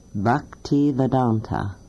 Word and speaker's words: Bhaktivedanta: And I think that his name is pronounced Bhaktivedanta, Swami A.C. Bhaktivedanta.